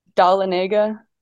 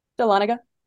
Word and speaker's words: Dahlonega: do they pronounce it? – Dahlonega. – And say it again how you pronounce it. – Dahlonega. – Dahlonega.